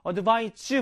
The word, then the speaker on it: advise: 발음도 차이 있어요. advice, advise입니다. advise. 자, advice, 뭐? 충고. advise, 뭐?